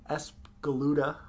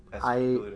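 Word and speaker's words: Espgaluda: It's Escaluda. – You did it. You said it right. – Yep. Escaluda.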